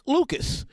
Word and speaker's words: Lucas: Playing in the game did not affect his performance at all, as he put on another virtuoso performance before beating Lucas, proper pronunciation is Luca, because I believe Eric Luca was a French-Canadian, before Luca retired in his corner after the 11th round.